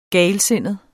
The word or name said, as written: galsindet